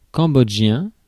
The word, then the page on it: cambodgien: France; /kɑ̃.bɔd.ʒjɛ̃/